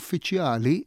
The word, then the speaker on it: uffiċjali: Issa, meta aħna mbagħad niġu għal kliem liħol li semmejt uffiċjali, pereżempju, jew uffiċini, l-aċċent m'għadux fuq iċċ. Imma fil-każ ta' uffiċjali, l-aċċent sar fuq ja, fuq is-sillaba ta' qabel tal-aħħar. U l-istess uffiċini fuq ċi.